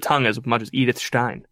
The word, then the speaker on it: Stein: He's like, "Is it offensive to pronounce Stein in a like that?" – No, it's not. That's how you pronounce it.